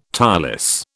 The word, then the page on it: tireless: British English